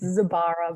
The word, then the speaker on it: Zibara: It's Zibara, but you're Zibara. Close enough.